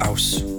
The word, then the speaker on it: Ouse: Or D, River Great Ouse. Pretty sure that's how you say it. House without the H.